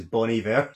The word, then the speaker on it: Bon Iver: No, that's how you say his name. It's Bon Iver.